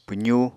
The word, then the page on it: pwzu: Apatani